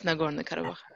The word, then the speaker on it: Nagorno-Karabakh: And just to track back, so Nagorno-Karabakh, is that how you pronounce it? – Yes, Nagorno-Karabakh.